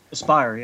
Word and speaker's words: Aspyr: Yeah, Star Wars Knights of the Old Republic are, I'm not even sure I can pronounce it, by Aspyr Media, Aspyr. – Aspyr, yeah.